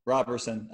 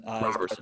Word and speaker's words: Roberson: How do you pronounce it? – Roberson. Roberson. Dawson corrected me on the last podcast I had him on.